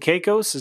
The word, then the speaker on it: Caicos: Cause we were talking about Turks and Caicos. Is that how you pronounce it? – Yeah.